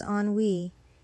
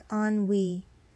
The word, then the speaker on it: ennui: The word is pronounced as ennui. It's a two-syllable word with the emphasis on the second syllable. So when you say it out loud, it should sound like ennui.